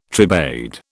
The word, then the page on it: tribade: British English